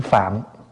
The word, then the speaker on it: Phạn: Chữ Phạn á, đúng ra mình đọc là chữ Phạm.